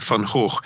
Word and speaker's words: Van Gogh: I think that the Dutch are the only ones who can probably lay claim to the correct pronunciation. I'm told it's something like Van Hoog, right.